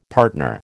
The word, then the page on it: partner: American English